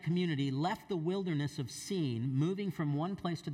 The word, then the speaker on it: Sin: It looks like it's sin, but it's Sin. It's pronounced Sin.